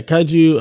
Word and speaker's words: Kaiju: Is that how you say it? Kaiju? – Yeah, Kaiju.